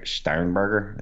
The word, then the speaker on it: Sternberger: As are most of the dynasty community, especially with them adding Jace Sternberger.